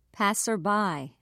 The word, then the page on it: passerby: British English